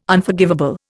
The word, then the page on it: Unforgivable: Indian